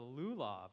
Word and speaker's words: lulav: And together, I'm going to butcher this word, you're going to know this, the lulav, I don't know how to say it, but yeah, I got an okay.